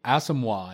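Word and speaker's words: Asomugha: number three on my list goes to Nnamdi Asamoah. I think that's how you say it.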